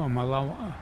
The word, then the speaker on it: Malawi: That M-A-L country? – Oh, Malawi? – Yeah.